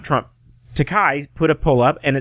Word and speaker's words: Takei: And speaking of stupidity, Jim, we'll move along now because here's my favorite stupid person on the internet, and I hope I pronounce his name right. George Takei. Did I say that right? Yeah, George Takei, yep.